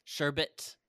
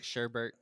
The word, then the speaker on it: sherbet: Sherbet. – Sherbet only. – Do not pronounce it Sherbert. That's not how you pronounce that word.